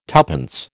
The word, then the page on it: tuppence: American English